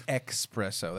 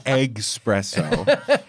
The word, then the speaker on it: espresso: It's espresso. That's how they say it. – Oh, God. It's egg-spresso.